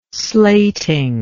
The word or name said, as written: slating